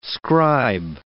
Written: scribe

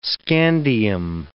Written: scandium

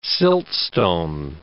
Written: siltstone

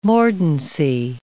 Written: mordancy

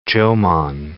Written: Jomon

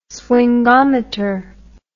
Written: swingometer

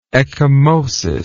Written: ecchymosed

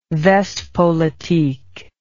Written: Westpolitik

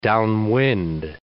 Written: downwind

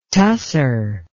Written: tussor

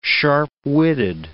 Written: sharp-witted

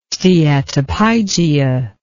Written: steatopygia

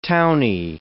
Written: towny

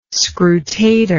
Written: scrutator

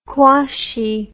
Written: quashie

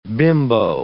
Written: bimbo

